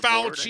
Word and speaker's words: Fauci: I think what the Republicans did, the Fucci, how do you say that name? I think that was Mr. Fauci. I mean, during my time, he's Mr. Fauci.